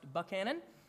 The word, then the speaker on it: Buchanan: Looks like it's spelled Buchanan, but no, it is pronounced Buchanan.